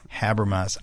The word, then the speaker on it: Habermas: The book comes with a foreword by none other than Gary Habermas. Is that how it's pronounced? – Yes. – Habermas.